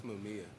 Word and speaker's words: Mamiya: I'm not sure if I'm saying it right. Mamiya, Mamiya, whatever. – Yeah, it's Mamiya.